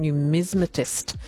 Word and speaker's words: numismatist: Also, Deb on the text line correcting my pronunciation on numismatist. There we go. It's an extra syllable. I was just missing that out. Thanks, Deb, for that correction. Numismatist.